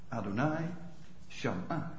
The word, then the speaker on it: Adonai Shama: Adonai Shama. Zo moet je het dan uitspreken. Adonai Shama.